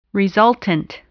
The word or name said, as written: resultant